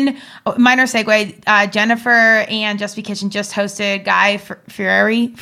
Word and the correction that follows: Fieri: I actually with Diners, Dive-Ins and Drives, watch it, but do I say his name right? – Guy Fieri. – Fieri.